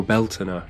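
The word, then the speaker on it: Bealtaine: Marking the start of Samos, the light half of the year, ergo the beginning of summer, we have Beltane, or Beltana, as it's pronounced in Irish Gaelic.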